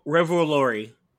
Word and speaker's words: Revolori: Revolori? – Revolori.